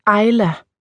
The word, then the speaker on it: Isla: De fleste kalder mig Isla, men det udtales Ejla, ligesom det engelske ord for ø, island, bare uden nd.